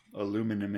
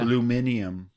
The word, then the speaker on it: aluminium: Aluminum in. – Yeah. Aluminium as the British say.